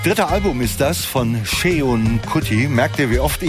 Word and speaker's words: Seun: Sheon Kuti ist der jüngste Sohn der Afrobeat-Legende Fela Kuti, schreibt sich S-E-U-N, und ich habe heute verzweifelt meinen WDR-Kumpanen aus frühester Zeit, Francis Gay, angeschrieben, der mir dann auch prompt zu Hilfe kam und sagte: Sheon, so spricht sich das aus.